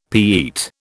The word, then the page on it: Pete: British English